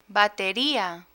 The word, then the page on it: batería: Spanish